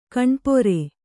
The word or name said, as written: ಕಾಣಿಪೇರು